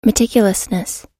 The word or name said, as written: meticulousness